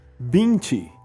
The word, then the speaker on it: Binti: Binti, de Ned Okorafor. Bint se escreve B-I-N-T-I e Ned Okafor se escreve N-N-E-D-I O-K-O-R-A-F-O-R.